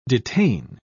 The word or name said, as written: detain